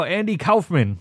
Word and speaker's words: Andy Kaufman: And he starts talking about Andy Kaufman, and I'm like, oh, Andy Kaufman, as Bill After told me is the right way to say it.